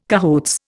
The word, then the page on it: cahoots: Indian